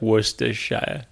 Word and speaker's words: Worcestershire: I actually don't know, but I think it's like, I'm pretty sure you ignore some of those middle letters and it's just like Worcestershire.